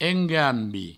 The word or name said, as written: engambi